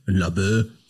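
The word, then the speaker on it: Laboe: Ich spreche jetzt einfach mal so aus, wie ich es als Berliner ausspreche. Labö.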